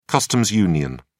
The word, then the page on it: customs union: British English